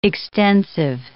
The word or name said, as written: extensive